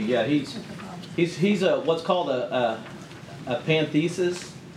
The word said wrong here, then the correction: pantheist: What is that? A pantheist, I guess you would actually pronounce it that.